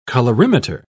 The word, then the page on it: colorimeter: British English